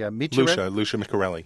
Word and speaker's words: Lucia Micarelli: Lucia Micarelli.